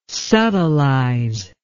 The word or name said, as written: subtilize